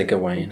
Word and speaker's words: Gawain: Here's my question for you. Gawain, Gawain, Gavin... – I say Gawain.